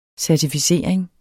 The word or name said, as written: certificering